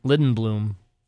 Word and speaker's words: Lindblom: The Korean MVP Lidblom. – You can't even say his name right. – What is it? Lindblom? Lindblom? – Lindblom.